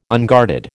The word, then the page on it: unguarded: American English